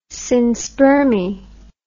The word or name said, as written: synspermy